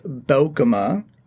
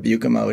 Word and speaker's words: Beukema: So it's pronounced Beukema. – Beukema, which is instead of Beukema, which is the way I've been saying it forever.